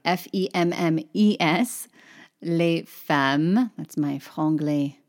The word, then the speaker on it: femmes: And notice I did not pronounce the S on the end of femme, right? Because that's, that's the end of my little phrase. There's no word with a vowel that follows it. So, les femmes, the women.